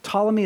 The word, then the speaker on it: Ptolemy: Ptolemy is spelled with a P. It's a silent P.